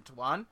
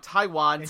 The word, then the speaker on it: Taiwan: And CJ Ride, an American Twitch streamer who's made a name for himself broadcasting his life in Taiwan. God, I said it wrong. Taiwan.